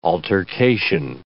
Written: altercation

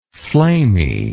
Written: flamy

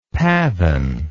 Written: pavin

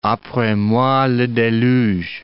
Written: apres moi le deluge

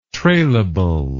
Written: trailable

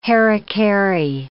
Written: hari-kari

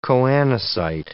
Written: choanocyte

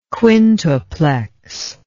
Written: quintuplex

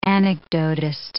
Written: anecdotist

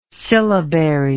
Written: syllabary

